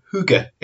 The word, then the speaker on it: Hygge: This is how it's pronounced. It's not how it looks as it's spelt. So Hygge is how it is pronounced.